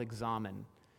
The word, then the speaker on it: examen: There's a discipline I really appreciate called examen. It's spelled like examine, but with an E at the end, so you get to say examen.